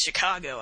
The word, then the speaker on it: Chingachgook: For instance, one of his acute Indian experts, Chingachgook, pronounced Chicago, I think, has lost the trail of a person he is tracking through the forest.